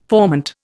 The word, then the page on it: formant: Indian